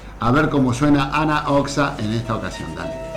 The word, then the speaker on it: Anna Oxa: Anna Oxa, que se escribe Anna WN, Oxa con X.